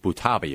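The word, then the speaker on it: Butabi: Chris, this was based off the characters developed for SNL, Doug and Steve Buttabi. Am I saying that right? – Yes.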